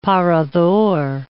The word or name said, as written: parador